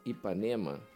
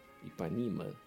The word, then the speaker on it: Ipanema: Bom, aí vem o seguinte: em 1965, é, ele faz um disco chamado Guitar from Ipanema, Ipanema, pronúncia americana, né?